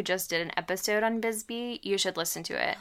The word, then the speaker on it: Bisbee: Bisbee, Arizona. I hope I'm saying that correctly. – It is. It's Bisbee, and it's haunted.